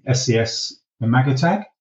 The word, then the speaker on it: SES-imagotag: SES-imagotag, I think that's how it's pronounced. – That's although correct, yeah.